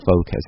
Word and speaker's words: focus: 在读 focus 这个词的时候大家一定要注意不要读成 focus 啊，很多读美音的同学说 I'm speaking American accent so it's focus， No it's not right， it's actually focus， No matter you're speaking American accent or British accent it's focus 啊，没有 R 字母的时候是打死不能卷舌的，不管你是讲哪类英语。